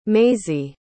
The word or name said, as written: maisie